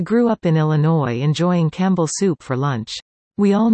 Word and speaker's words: Campbell: Then we moved to the Mahoning Valley and discovered the city of Campbell was pronounced like the animal camel.